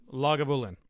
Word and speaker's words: Lagavulin: Um, a lot of these are scotches, of course, and then some of the peaty ones, you know, like the Lagavulin. Lagu, How do you how do you pronounce it? – Lagavulin.